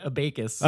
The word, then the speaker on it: abacus: Abiscus. – He calls it abacus.